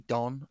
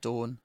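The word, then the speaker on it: Dôn: Without it, without the circumflex above it, it would be Don, as in Don Cheadle. But because it's got the circumflex, it's pronounced Dawn, as in, you know, from dusk till dawn.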